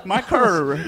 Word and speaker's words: Michael: Yeah, and you pronouncing Michael as Markal. – Michael. – Michael.